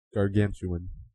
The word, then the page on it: gargantuan: American English